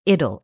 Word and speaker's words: idyll: Now, a note on the pronunciation of that word, I-D-Y-L-L. In England, they pronounce that idyll, and generally in America, they pronounce it idyll.